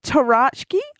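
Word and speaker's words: tarocchi: So the original deck was actually used to play a bridge-like game known as Tarachki. I hope I'm saying that right. – Which sure you nailed it.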